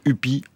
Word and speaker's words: Ypi: On prononce Huppi alors c'est, que ça s'écrit YPI parce que vous êtes d'origine albanaise, même albanaise.